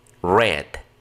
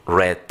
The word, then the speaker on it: read: En presente se dice read, en pasado read y en pasado participio read también, como el color rojo, así es como se pronuncia.